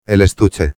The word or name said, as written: el estuche